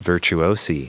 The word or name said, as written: virtuosi